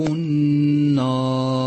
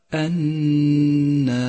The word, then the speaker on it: النار: ثانيا اقرأ كلمة النار، اقرأها بشكل صحيح ولاحظ أن جميع حروفها مرققة، فإياك والتفخيم في هذا الموضع كأن تقول أصحاب النار فتفخم الألف مثلا، بل كل حروفها مرقق، أي هكذا النار.